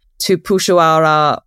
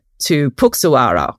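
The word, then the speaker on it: Tupuxuara: And also you and Marina were kind enough to also correct us because we were pronouncing tupushuara wrong as well. That word has an X in it, but we had been saying it to Puxuara, which isn't correct. So yeah, it was really great to meet everyone and just learn so much about all these different types of pterosaurs.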